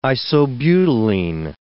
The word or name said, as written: isobutylene